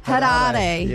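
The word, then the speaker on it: Harare: Which landlocked African country has Hariri? I don't know if I'm saying that right. – No. It sounds like you are. – Hariri.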